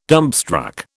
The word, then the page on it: dumbstruck: British English